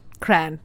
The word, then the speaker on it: crayon: Crayon. – Because it is a one-syllable word to me. – It is a two-syllable word to me. – Not to me. I grew up saying crayon. Cran.